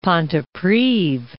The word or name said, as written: Pontypridd